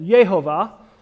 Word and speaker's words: Jehovah: There's actually no hard J sound in the Hebrew alphabet, so many people pronounce it Yehovah.